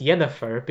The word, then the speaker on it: Yennefer: Like, it's still meant to be pronounced Yennefer, because that's how you'd pronounce it if you transliterate Yennefer into Lithuanian orthography.